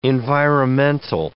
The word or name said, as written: environmental